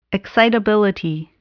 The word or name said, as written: excitability